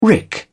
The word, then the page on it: rick: British English